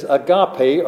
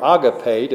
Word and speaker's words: agape: That in the Greek is agape or agape, depending on which way you're used to pronouncing it. A-G-A-P-E as we had spelled it in the English.